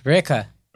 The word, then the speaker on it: Reyka: Reykja. – Reykja? – Yeah. – Reykja. – How you spell it? – G. No, R-E-Y-K-A.